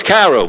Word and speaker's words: Cairo: Am I pronouncing that right? Do you remember? Cairo Prince. It's either Cairo Prince or Cairo Prince. Cairo. Yes, it's Cairo because it's...